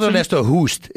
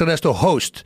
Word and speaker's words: Ernesto Hoost: Yeah, it's not Ernesto Hoost. It's Ernesto Host.